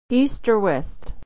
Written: or west

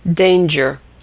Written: danger

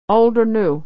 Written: or new

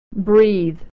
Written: breathe

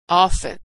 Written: often

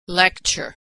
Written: lecture

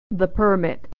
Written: the permit

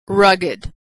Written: rugged

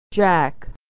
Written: jack

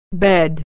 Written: bed

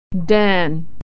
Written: Dan

